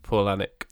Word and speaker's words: Palahniuk: It's pronounced Palahniuk.